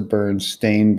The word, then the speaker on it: Berenstain: So anybody who isn't aware of this, it's actually spelled S-T-A-I-N. It's the Berenstain Bears.